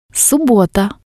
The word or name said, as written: subbota